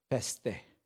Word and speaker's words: pistis: So whichever is like, I was like, And the word basically says peste, or it's spelled P-I-S-T-I-S, which means faith or faithfulness.